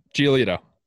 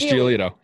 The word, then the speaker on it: Giolito: Giolito. Lucas Giolito, yeah.